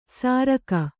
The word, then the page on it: ಸಾರಕ: sāraka